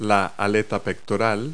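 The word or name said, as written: pectoral fin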